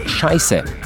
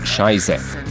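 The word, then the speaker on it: Scheiße: Zato je treba opozoriti, da se tudi pri Slovencih tako priljubljena beseda izgovori šajse in ne šajze, saj se napiše z ostrim S.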